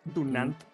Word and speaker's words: Dunant: La Cruz Roja es un movimiento humanitario mundial de características particulares y únicas en su género y fue fundado por Henry Durant o Henry Durant, la neta, no sé cómo se pronuncia bien, güey. Dunant, perdón, güey.